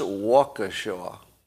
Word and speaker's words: Waukesha: It's pronounced Waukesha. Waukesha. So I've been pronouncing it Waukesha.